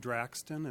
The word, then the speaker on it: Draxten: My name is Brian Draxton, and that's spelled D-R-A-X-T-E-N.